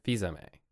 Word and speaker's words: Fils-Aimé: A great record called Three Little Words from Dominique Fils-Aimé. Am I pronouncing that right? – Fils-Aimé. – Okay. Fizame, who does like a jazzy, like Etta James style modernized vocals.